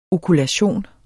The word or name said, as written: okulation